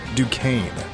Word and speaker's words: Duquesne: BYU versus an 11 seed, pronounced Duquesne.